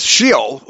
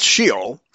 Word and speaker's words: Sheol: It is Sheol. Okay, here it's Sheol. – Sheol. – Sheol. Sheol. Yeah, there's a couple of different pronunciations here.